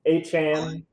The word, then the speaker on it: Achane: Achane, Achane actually. is the correct way to say it.